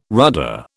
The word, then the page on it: rudder: British English